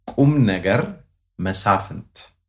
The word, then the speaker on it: Kumneger Mesafint: So my name is Kumneger Mesafint.